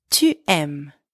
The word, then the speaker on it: aimes: J'aime, tu aimes, you like or you love, tu aimes. And don't forget the silent S at the end.